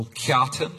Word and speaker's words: Kjartan: There is a passage about your uncle Kjartan.